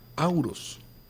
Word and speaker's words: Horus: Y está también Horus, que realmente se pronuncia Auros, que es el dios del sol, dios de la luz, dios del nacimiento de la conciencia.